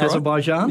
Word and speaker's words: Azerbaijan: Azerbaijan, is that how you say it? – That's Azerbaijan. – Right. Yep.